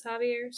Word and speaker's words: Javier: We're going to start with Javier, which when I read it, it reads like Xavier, but the French pronunciation is Javier.